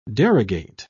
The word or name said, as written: derogate